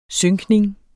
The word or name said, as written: synkning